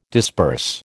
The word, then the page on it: disburse: American English